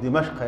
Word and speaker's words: دمشق: شام وہ شہر ہے دمشق, جس کا صحیح تلفظ دمشق ہے,